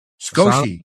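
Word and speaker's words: sukoshi: Sakoshi. Oh, you don't pronounce the first vowel sound. That's a little lesson in Japanese for you.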